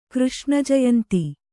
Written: ಕೃಷ್ಣಜಯಂತಿ

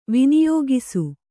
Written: ವಿನಿಯೋಗಿಸು

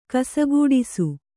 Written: ಕಸಗೂಡಿಸು

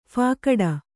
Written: ಫಾಕಡ

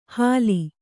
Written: ಹಲಿ

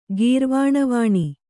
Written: ಗೀರ್ವಾಣವಾಣಿ

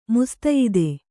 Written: ಮುಸ್ತಯಿದೆ

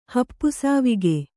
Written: ಹಪ್ಪುಸಾವಿಗೆ

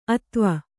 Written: ಅತ್ವ

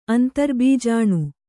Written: ಅಂತರ್ಬೀಜಾಣು

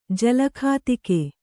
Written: ಜಲಖಾತಿಕೆ

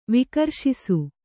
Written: ವಿಕರ್ಷಿಸು

